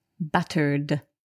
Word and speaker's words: battered: So, battered, we can hear there that the ed is pronounced d, battered.